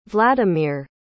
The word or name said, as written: vlademir